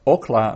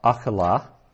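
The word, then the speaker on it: okla (אָכְלָה): So that's pronounced okla, not akala.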